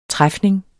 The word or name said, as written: træfning